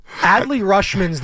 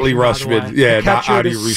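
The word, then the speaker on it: Adley Rutschman: Audie Rushman, who we're going to see in the home run derby. – Adley Rushman's the pronunciation. – Adley Rushman, star catcher, one of the best in the big leagues for the Baltimore Orioles.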